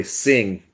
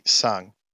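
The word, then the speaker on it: Tseng: Surely Sing. Surely it's a silent T. – Sing is definitely sung.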